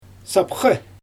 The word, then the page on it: sapxy: Xyzyl